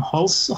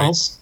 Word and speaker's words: Hulce: Murray Abraham and Tom Hulce. How do you say his name? – I think you got it. – Hulce.